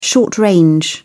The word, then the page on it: short-range: British English